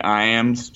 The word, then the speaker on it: Iams: If you want to find my personal account, they call me Iams, just like I said earlier. Iams is spelled just like the dog food.